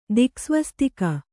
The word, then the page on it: ದಿಕ್ಸ್ವಸ್ತಿಕ: diksvastika